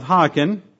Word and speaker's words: Haakon: I had to look up how to pronounce that. Of Haakon.